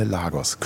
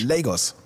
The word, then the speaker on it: Lagos: Weil in Deutschland sagen immer alle Lagos. Könnte ich mich aufregen. Es heißt Lagos.